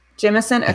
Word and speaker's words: Jemisin: Jemisin.